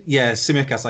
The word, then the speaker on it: Tsimikas: I know they got, was it Tsimikas? I never know how to pronounce his name. – Tsimikas, I go with.